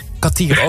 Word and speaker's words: Kartier: Hoe spreek je jouw achternaam uit? – Kartier. – Oh, ik dacht misschien Cartier.